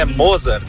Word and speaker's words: Mozart: Bom, então, já que erra sempre meu nome, então a pronúncia é Mozart. Mozart da Luper Confecções, e a minha eterna namorada é a diretora da Luper Confecções.